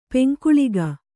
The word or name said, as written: ಪಿಂಕಳಕ